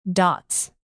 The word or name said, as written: dots